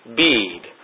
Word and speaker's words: Bede: The Venerable Bede, and that's how you pronounce it, Bede,